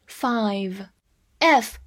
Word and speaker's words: five: f i v e five，f 发 f，注意咬下嘴唇。字母 i 发它本身的音，v 发 v 也是咬下嘴唇。最后的字母 e 不发音。